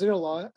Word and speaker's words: Zeroa: So can you tell us about Zeroa, first of all, Zeroa, and also if I'm pronouncing that correctly, and its new product? – Yeah, it's hard to pronounce. Yeah, it's pronounced Zeroa.